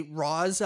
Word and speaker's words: Ra's: like Raj, like R-A-G almost? – Ra's. – Ra's yeah. al Ghul, yeah, from Batman.